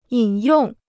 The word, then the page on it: 引用: yǐn yòng